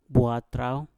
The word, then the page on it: bwatrau: Mpur